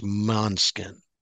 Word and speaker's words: Måneskin: But it's actually, I believe, pronounced Måneskin.